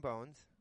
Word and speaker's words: Bownes: Did you ever play on the same team as Fabian Bones? B-O-W-N-E-S. Fabian Bones or Bonus, one of those others. – Fabian Bones, Arizona Cardinals, Gunner, right?